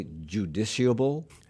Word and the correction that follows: justiciable: Justiciable. – Okay. – There's justice.